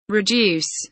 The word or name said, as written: reduce